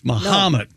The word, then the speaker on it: Mahomet: The H-O is pronounced like ha, not like ho. – So it's not Mahomet? – It's Mahomet.